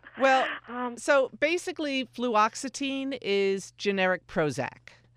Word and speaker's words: Fluoxetine: I hope I'm pronouncing it correctly. Fluoxetine. – Fluoxetine, yep.